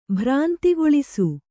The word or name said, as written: ಭ್ರಾಂತಿಗೊಳಿಸು